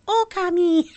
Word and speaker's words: Okami: Or Okami. That's how they say it on you press start and it goes Okami.